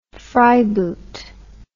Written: fryeboot

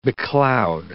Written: becloud

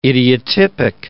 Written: idiotypic

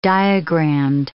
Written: diagramed

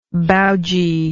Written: baoji

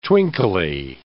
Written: twinkly

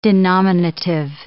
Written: denominative

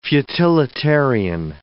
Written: futilitarian

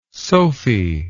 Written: sophi

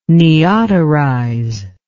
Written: neoterize

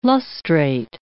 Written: lustrate

